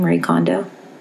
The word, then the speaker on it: Kondo: And I, when I was listening to an interview with Marie Kondo, is that how I say her name, Marie Kondo? – Yeah.